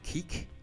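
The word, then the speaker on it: Quique: That's Plain Song by Seafeel, ending tonight's programme from their album Keek, pronounced Keek, and spelt, I think, Q-U-I-Q-U-E. I know that's how it's spelled.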